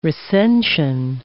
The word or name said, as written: recension